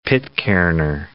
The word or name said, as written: Pitcairner